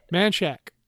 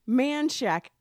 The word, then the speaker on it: Manchaca: Yeah, how do you say it? – Manchac. – Manchac. I always say Manchego because that cheese is good. – Well, it also looks like manchaca.